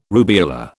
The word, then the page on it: rubeola: British English